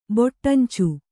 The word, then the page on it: ಬೊಟ್ಟಂಚು: boṭṭancu